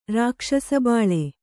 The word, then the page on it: ರಾಕ್ಷಸಬಾಳೆ: Kannada